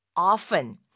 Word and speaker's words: often: 자, 이번에는 sometimes보다는 조금 더 빈도수를 높여서 종종 또는 자주 라는 뜻으로 말할 때 쓰이는 often, often을 알아보겠습니다. O-F-T-E-N으로 써서요. 이걸 often에서 중간에 오는 T를 묵음으로 발음을 하지 않고 often 하고 말하는 경우도 있고 often, often 해서